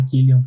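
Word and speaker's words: Cillian: Uh, eu só descobri que o nome dele se pronunciava Killian por causa de uma entrevista da Margot Robbie sobre as premieres lá do, do, do Barbie e do Oppenheimer.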